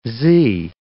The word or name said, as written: z